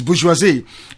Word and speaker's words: bourgeoisie: The northern bourgeois and the Yoruba bourgeoisie, let us pronounce it correctly as the French will say, it's bourgeoisie, and the Yoruba bourgeoisie have conspired to keep the Igbo people out of the scheme of things.